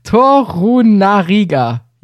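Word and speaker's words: Torunarigha: Du hast mit Torunaia, sorry für die Aussprache. Torunariga, jetzt. – Richtig.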